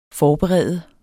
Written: forberede

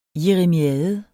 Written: jeremiade

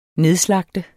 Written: nedslagte

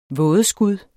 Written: vådeskud

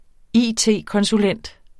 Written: it-konsulent

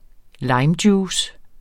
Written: limejuice

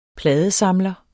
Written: pladesamler

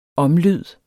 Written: omlyd

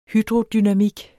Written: hydrodynamik

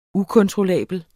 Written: ukontrollabel

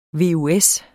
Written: VUS

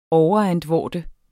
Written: overantvorde

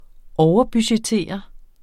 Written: overbudgettere

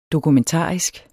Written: dokumentarisk

